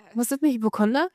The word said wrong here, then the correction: Hypochonder: Hypochonder. Hypochonder heißt das. Hypochonder.